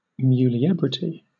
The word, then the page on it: muliebrity: British English